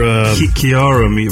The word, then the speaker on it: Kiara Mia: Kyra Mia, 41. Look her up on your... – Kiara Mia, right? Spell it. – K-I-A-R-A. – Kiara, right? – Kiara Mia.